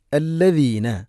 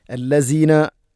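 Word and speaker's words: അല്ലദീന (الذين): അല്ലദീന എന്നുള്ള ഈ പദം അല്ലെങ്കിൽ ദാലിക, ഇദൻ, അഊദു ഒക്കെ ഇവിടെയെല്ലാം ദാലിൻ്റെ ഉച്ചാരണം അല്ലെങ്കിൽ ആ ഒരു ശബ്ദം പലപ്പോഴും മാറും അധികം ആളുകളിലും. അല്ലസീന, ഔദു, ഇസൻ, ജാലിക ഇങ്ങനെയൊക്കെയാണ് ധാരാളം ആളുകൾ പറയാറുള്ളത്. അതുകൊണ്ട് സായോ ദായോ ഒന്നുമല്ല.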